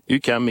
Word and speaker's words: UQAM: Mais vous êtes, comment ça se prononce ? UQAM, comment dites-vous ? – UQAM, exactement.